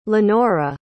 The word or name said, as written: lenora